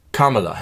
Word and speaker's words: Kamala: Aussprache einmal, ich habe es inzwischen gelernt, wir haben es häufig falsch gemacht, du gerade eben auch. Kamala Harris.